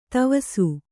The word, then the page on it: ದವಸ: Kannada